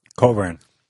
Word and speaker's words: Cockburn: Anybody who's familiar with Counterpunch, Andrew Cockburn is a brother of Alexander Cockburn. I'm pronouncing the name wrong. – Coburn. – Yeah. The other one sounds like a... – Silent CK.